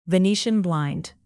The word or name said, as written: venetian blind